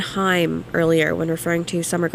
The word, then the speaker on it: Haim: I got a text message from Emily, who is I've been talking about the whole show, and Emily said it's pronounced Haim.